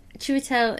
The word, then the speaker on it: Chiwetel: Chiwetel is how you pronounce his first name.